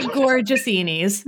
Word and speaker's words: Tzortzinis: You may have betrayed some of your feelings about me, but it's pronounced Tzortzinis. – Andy Tzortzinis.